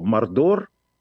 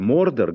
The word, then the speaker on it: Мордор: Но я не думаю, что Мордор или Мордор, где-то там ставится ударение, меня больше радует Мордор, потому что от слова морда, хотя не от этого слова.